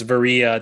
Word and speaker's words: Varia: So it's Varia. It's like Maria, but with a V.